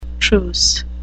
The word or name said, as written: truce